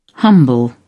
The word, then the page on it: humble: British English